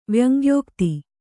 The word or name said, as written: ವ್ಯಂಗ್ಯೋಕ್ತಿ